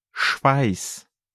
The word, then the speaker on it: Schweiß: Schweiß.